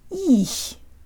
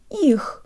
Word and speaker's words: их: On its way from the e vowel to the release of the h, the tongue actually falls or moves to a lower position in the mouth. If it didn't, the word would sound like this. Ich. Ich. Instead, we have a darker sound at the end, and we can hear the fall if we slow it way down.